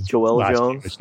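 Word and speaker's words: Joëlle Jones: I always get one of the parts of the name wrong, but Miguel Yannin and Jolie Jones. I mean, they're just killing it on these. – Joel Jones, you mean? – What did I say? – Jolie. – Okay, Joel.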